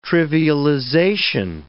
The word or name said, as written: trivialization